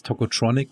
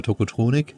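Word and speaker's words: Tocotronic: Ja, auch das wieder so ein Beispiel, also sie singen ja dann selber Tokotronic, This Boy is Tokotronic, äh, auch englisch ausgesprochen, ne? – Mhm. Früher, also als ich sie kennengelernt habe, hieß es immer Tokotronic. – Mhm. Ähm, Geht beides.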